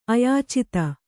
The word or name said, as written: ಅಯಾಚಿತ